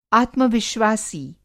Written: आत्मविश्वासी

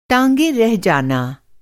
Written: टाँग

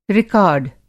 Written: रिकार्ड

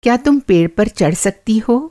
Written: चढ़ना

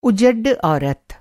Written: उजड्ड

